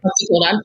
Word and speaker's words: OxyContin: I was prescribed once Oxycontin. I never say it right. – Oxycontin, yeah.